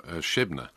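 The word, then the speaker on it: Shebna: Yeah, Shebnah.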